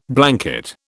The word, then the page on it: blanket: British English